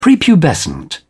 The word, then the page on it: prepubescent: British English